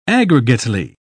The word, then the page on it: aggregately: British English